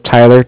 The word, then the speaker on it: Tyler: My name is Tyler, T-Y-L-E-R, and I'm with Bank of Cardiff. It's spelled C-A-R-D-I-F-F, as in Frank, Frank.